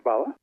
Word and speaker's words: spalla: You talk about the big eight in the book, prosciutto, salami, coppa, lardo, spalla, do you pronounce it? – That's correct, spalla.